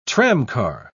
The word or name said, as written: tramcar